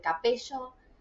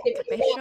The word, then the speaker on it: Capello: se pronuncia capelo, ¿no? o capello. Exacto, capello. Capelo, pero se escribe capello con doble e. Exacto. Mimi, ¿cómo estás? Problemas en el apellido, siempre capello. Eh, ¿Viste? capello.